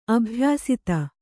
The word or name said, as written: ಅಭಯಸ್ತ